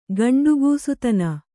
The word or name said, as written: ಗಂಡುಗೂಸುತನ